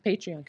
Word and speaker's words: Patreon: Did I say that right, or is it Patreon? – Patreon. – It patrons. Is Patreon. Yeah. Patreon. – Yeah. Because they're patrons, so they're tomato, tomato.